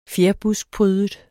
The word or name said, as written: fjerbuskprydet